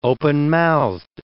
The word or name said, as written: openmouthed